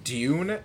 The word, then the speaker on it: Dune: It's pronounced Dune, actually. The U is long. Yeah, we're talking Dune. We are talking D-U-N-E.